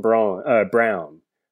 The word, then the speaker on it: Braun: and he goes by the name of Christian Brown. Even though it's spelled Braun, he's pronounced it Brown. Kansas, wing for Kansas. He was the guy. You know, here's my hot take is that Christian Braun will be a better pro than Oshae Ojaibi.